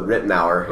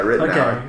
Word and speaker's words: Ritenour: You know, how did you pronounce it? – Yeah, it's always the Ritenour. – Ritenour. – Yeah, Ritenour. Okay.